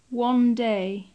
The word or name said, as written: one day